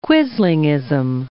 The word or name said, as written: quislingism